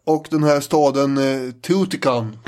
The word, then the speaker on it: Teotihuacan: Ja, det här är Nationalencyklopedin, vi fick slå upp uttalet. – Ja, men någonting i stil med Teotihuacan. – Teotihuacan.